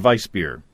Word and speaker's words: Weissbier: Is it Weissbier or Weissbier? – I think the German pronunciation is Weissbier.